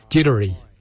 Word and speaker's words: Djerdari: Jardak is spelled D-J-A-R-D-A-G. Jardak, grey. We'll now look at the word for brown. Jittery. Jittery is spelled D-J-E-R-D-A-R-I. Jittery.